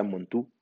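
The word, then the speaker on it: Mantoux: Bueno, para 1908, Montoux, que creo se pronuncia Montu, eh, describió ya cómo hacer la prueba y es como hacemos la prueba hasta el día de hoy.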